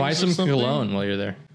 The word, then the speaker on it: Cologne: But in Russian, in English, it's Cologne, like colon.